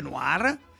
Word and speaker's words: Noir: Allora, si scrive Noir, si legge Noir.